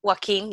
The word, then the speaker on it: Joaquin: Is it Joaquin Phoenix? – Joaquin, yep.